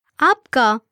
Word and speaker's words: Aapka: Aapka is a formal or polite possessive pronoun we use for singular, plural, masculine or feminine person, and it literally means your. Let's break it down by syllable. Aap ka.